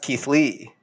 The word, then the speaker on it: Keithleigh: It's pronounced Keithley. It's spelled K-E-I-T-H-L-E-I-G-H. Like Ashley.